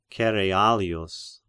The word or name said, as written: Cerealius